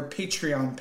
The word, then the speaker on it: Patreon: Am I saying that correctly? – That's it. Patreon? I think that's it. Yeah, not what were you calling it? Palladium? – Patreon. – No, you're calling it something. Yeah, Palladium Con.